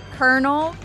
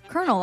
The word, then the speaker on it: colonel: I think I'm going to go with kernel, because it's actually spelled like colonel, mm-hmm. but it's kernel, like a popcorn kernel.